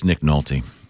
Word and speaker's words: Nick Nolte: Yeah, that's Nick Nolte.